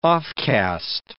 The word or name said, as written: offcast